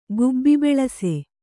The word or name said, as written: ಗುಬ್ಬಿಬೆಳಸೆ